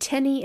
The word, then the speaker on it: Tenney: And Tenny is spelled T-E-N-N-E-Y.